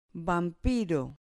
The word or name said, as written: vampiro